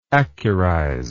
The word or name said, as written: accurize